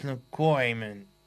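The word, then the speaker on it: Locorriere: It's sad that we lost Way in 2018, and just the last few days, we lost Dennis McCoyman. That's how you say his name, from Dr. Hook.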